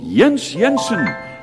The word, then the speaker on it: Jens Jensen: Jens Jensen, spelled with a J, pronounced like a Y.